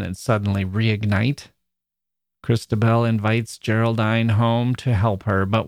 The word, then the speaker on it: Geraldine: It's about a woman named Christabel who's walking in the woods when she encounters a woman named Geraldine, or it seems Geraldine, pronounced like decline, who says she's been abducted by some men on horseback.